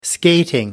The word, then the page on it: skating: American English